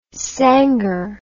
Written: sangar

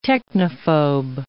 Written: technophobe

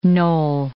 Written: knoll

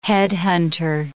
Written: headhunter

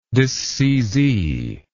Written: disseizee

